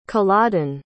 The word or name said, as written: culloden